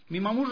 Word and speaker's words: Mimamú: Mimamú. Mimamú responde a tres sílabas, es mi nombre artístico cuando hago mimo o cuando hago teatro infantil.